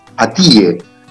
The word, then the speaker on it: Atiye: Se escribe Atiye, A-T-I, latina, Y-E, Atiye, es como se pronuncia en turco.